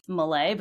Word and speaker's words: Melle: The Mel part of it, I know that that's very common. It's in my head, it's Melle, but that's only because it's actually a nickname that my fourth grade teacher gave me.